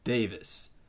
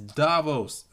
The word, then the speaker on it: Davos: instead of Davos, it's Davis. It's like nails on a chalkboard. It's like someone calling Thanos Thanos. Ugh. Like, stop it! It's not that. It's Davos, alright?